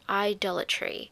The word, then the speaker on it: idolatry: and that is idolatry. How do you say that word? Idolatry. Or it's not adultery, it's idolatry. Okay, that's beside the point.